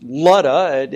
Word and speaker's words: Lydda: It's called Lud in the Old Testament, either L-O-D or L-U-D, depending on your Bible translation. And it's pronounced Lydda, even though there's a Y in it.